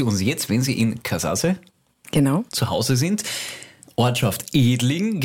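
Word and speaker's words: Kazaze: Der Treuebonus wartet immer noch bei uns und in der Zwischenzeit hat sich jemand gemeldet, hat uns aufgeklärt, dass es nicht Katasse ist, wie wir es ausgesprochen haben, aber wir waren uns da einfach nicht sicher. Haben wir nicht gewusst, wir haben wieder dazugelernt. Wird jetzt richtig ausgesprochen? – Katasse und ist natürlich die slowenische Bezeichnung von Edling.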